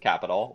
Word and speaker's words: Qapital: It's called Capital, Qapital. I used to call it Quapital all the time, but I guess they pronounce it Capital, whatever.